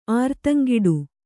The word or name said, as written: ಆರ್ತಂಗಿಡು